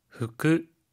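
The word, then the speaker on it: 후크 (服): 후크, 후크. – 옷, 복이라고 하는 한자를 그대로 음으로 읽었어요. 후크라고 하는데요.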